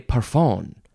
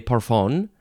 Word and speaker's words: parfum: Now it sounds like it ends in an F-A-N. Eau de parfum. That's sort of how the pronunciation is spelled, but it is spelled, that third word is P-A-R-F-U-M. I would want to say eau de parfum, but it's eau de parfum.